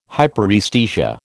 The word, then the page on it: hyperaesthesia: American English